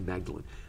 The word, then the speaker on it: Magdalen: You can walk around it at Magdalen College, which is the way the British say Magdalen. Magdalen, as in Mary Magdalene.